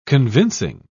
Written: convincing

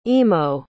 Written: emo

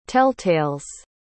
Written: telltales

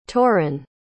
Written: taurin

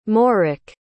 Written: moric

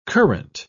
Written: current

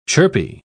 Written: chirpy

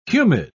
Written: humid

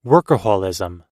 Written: workaholism

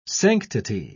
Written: sanctity

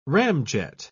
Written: ramjet

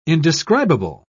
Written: indescribable

